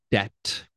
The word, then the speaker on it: debt: So you rack up debt. Remember, debt, the B is silent.